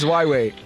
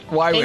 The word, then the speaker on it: Huawei: Till this day, I still can't pronounce Huawei. I don't know if it's Huawei or Huawei. – Your second attempt was right. – Huawei. No, I can't pronounce that word. – It's Huawei. – Huawei.